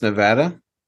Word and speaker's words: Nevada: Nevada, and it's pronounced Nevada. – Nevada. – It's not pronounced Nevada.